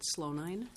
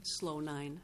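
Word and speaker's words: Slonine: My name is Marguerite Slownine. – Slownine? – Slownine. – Can you please spell that, please? – It's S-L-O-N-I-N-E.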